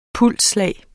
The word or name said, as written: pulsslag